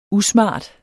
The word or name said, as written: usmart